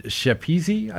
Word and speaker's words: Schepisi: It's directed by Fred Schepisi, I think is how it's pronounced, a fine Australian director.